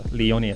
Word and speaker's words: Leoni: Um, where can they follow you if they want to follow your Leoni FC profile? What, what platforms can they follow you on? – Uh, they can follow us on Facebook and Instagram, and it's uh, Leoni FC.